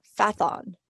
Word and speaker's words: Phaethon: It's known as 3200 Phaethon, or Phaethon.